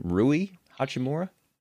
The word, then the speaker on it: Rui Hachimura: Rui Hachimura? – Yep.